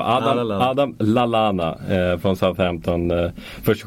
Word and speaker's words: Lallana: Så här, I'm Adam Lalana blev jag besviken på honom.